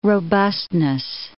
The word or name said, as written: robustness